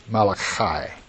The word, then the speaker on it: Malachi: It's in the Hebrew, it's Malachi. And probably the Italians would call it Malachi. But in English, we usually pronounce it Malachi, but it's really Malachi. Malachi. You know, that CH is, you almost have to spit with that one, right?